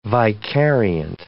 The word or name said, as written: vicariant